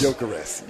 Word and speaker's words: Gyökeres: Am I saying that right? Gyökeres. – Gyökeres, yes.